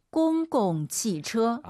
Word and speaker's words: 公共汽车: 公共汽车. – 아, 이거 초급자분들이 선생님 정말 많은 분들이 공공 두 번째 공도 일성으로 많이 해요.